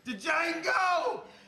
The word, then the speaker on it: Django: Django! You black son of a bitch! – The D is silent here, baby.